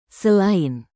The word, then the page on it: selain: indonesian